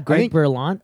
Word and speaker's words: Berlanti: Yeah, Greg Berlanti.